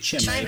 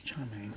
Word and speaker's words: Chimay: I was saying Chimay, but it's Chimay. Okay.